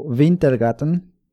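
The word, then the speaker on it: Wintergatan: Tengo un canal de YouTube que se llama, bueno, él lo pronuncia como Wintergatten, es un artista sueco, suizo, sueco, sueco creo.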